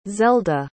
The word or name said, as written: zelda